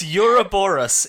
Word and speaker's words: Ouroboros: Yeah, it's Ouroboros in according to Wikipedia. Like, there's a Y sound at the beginning.